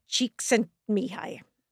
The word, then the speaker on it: Csikszentmihalyi: He lived in the US at the time, and he has one of those names that you really just have to learn how to pronounce the way I do it is phonetically. So it's Mihai Csikszentmihalyi.